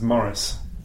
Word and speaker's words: Maurice: I used to live in, when I was a little kid, I lived in England and I was Morris. And then I moved to America, and they couldn't understand that you pronounced my name like that, so I just switched it.